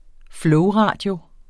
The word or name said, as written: flowradio